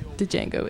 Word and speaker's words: Django: Silent D. – Django. The D is silent.